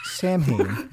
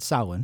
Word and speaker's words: Samhain: Then he started a band called Samhain. Yes, that is the way it's pronounced, because Glenn Danzig is from Lodi, New Jersey. So it is Samhain and not Samhain.